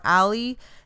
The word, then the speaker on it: Ale: So his name is Jose Alejandro Valencia, but he goes by, a lot of people pronounce it Ali or Ali. It's like short for Alejandro. So A-L-E is how he spells it. – Okay. – I think it's like Ale, Ali, Ale, something yeah.